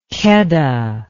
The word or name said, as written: keddah